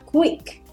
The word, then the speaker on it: Qik: ¿Cómo se pronuncia esto? Q-I-K, Quick. Quick. Una pequeña abreviación más rápida de la palabra Quick, que es con U.